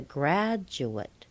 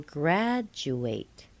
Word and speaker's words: graduate: I'm sure you've heard graduate. He's a graduate. But when we use the verb, he will graduate. What happened? Grad is still the primary stress. The second syllable is still weak, but the third syllable became a secondary stress.